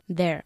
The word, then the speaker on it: they're: Como la palabra they're allí. – All right. – Con una sola sílaba. They're, ¿no? They're. Porque they're. Si no, ¿para qué está la contracción? – All right.